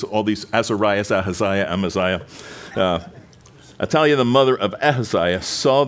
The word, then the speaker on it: Athaliah: Athaliah is how you would pronounce her.